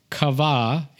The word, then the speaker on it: qavah: The word is kava. It's spelled Q-A-V-A-H, kava.